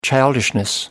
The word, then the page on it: childishness: British English